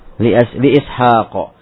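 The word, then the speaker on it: Li'ishaqo (إسحاق): Li'ishaqo. Ha walaupun harokatnya adalah pendek ya satu kali seperti harokat, tapi dia dibaca dengan panjang.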